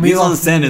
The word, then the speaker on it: mise en scène: Mise en scène is the correct way to pronounce it.